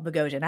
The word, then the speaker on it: Bogosian: Eric Bogosian, that's a hard last name to say, y'all. Bogosian, I had to like Google that, how to say his name properly, returns as Daniel Malloy.